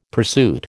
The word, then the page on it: pursuit: American English